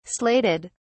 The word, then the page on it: slated: British English